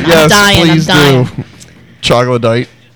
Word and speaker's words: Troglodyte: Troglodyte. – Troglodyte.